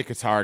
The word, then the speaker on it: Qatar: And that's the reason why a place like Qatar or Qatar. How do you say it, number one? – I've heard both. I say Qatar because that's what they're saying on the broadcast yesterday when I was watching.